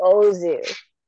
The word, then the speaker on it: Ozu: Starring Yasuhiro Ozu. – Okay, on the website that I have them pronounce it, they didn't have Ozu. They just said Zu. Then they were incorrect. – Because his last name ends with an O.